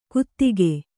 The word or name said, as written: ಗುದಿಕೆ